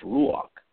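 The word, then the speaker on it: ruach (רוּחַ): The word spirit, as translated from the Bible, the Hebrew, 7304 in the Hebrew, is pronounced ruach.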